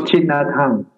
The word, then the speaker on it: Thich Nhat Hanh: You spoke earlier, I'm not sure if I'm going to get his name right, Thich Nhat Hanh, I think, the Vietnamese Buddhist monk. – Oh, Thich Nhat Hanh.